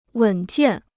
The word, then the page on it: 稳健: wěn jiàn